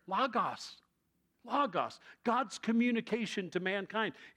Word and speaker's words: logos: It's not logos, but logos, because it's short O's and not long O's.